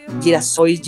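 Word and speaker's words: Girassóis: que se llama Girasóis de Van Gogh, sería la pronunciación correcta en portugués,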